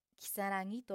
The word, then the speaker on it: 如月: 月のごとくと書いてきさらぎと読みま